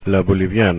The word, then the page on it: boliviana: Spanish